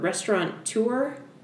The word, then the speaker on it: restaurateur: And he was a, how do you pronounce that word? Restaurateur? – Yeah. – Like, that's what I would say.